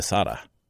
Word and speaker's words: Sade: His big takeaway from the Jeffrey Epstein specials we did is I pronounced the name Marquis de Sade, which I've heard it pronounced that way. I've heard it Marquis de Sade, which is most people say that. And then there's Marquis de Sada. Some people say it that way.